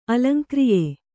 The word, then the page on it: ಅಲಂಕ್ರಿಯೆ: alaŋkriye